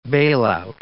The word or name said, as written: bailout